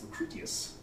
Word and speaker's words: Lucretius: Lucretius, which a lot of people mispronounce as Lucretius. One of the things about Latin, you pronounce every single letter. So it's Lucretius.